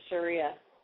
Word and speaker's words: Sharia: It's uh, pronounced Sharia. – Sharia, okay.